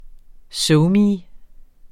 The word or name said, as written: SoMe